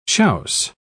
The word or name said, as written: chiaus